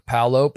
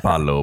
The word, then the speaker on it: Paolo: And I'm doing my whisper of that is how he pronounces it. He says Paolo. We've been told Paolo. – Paolo. – We've been, but it's Paolo.